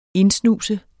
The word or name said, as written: indsnuse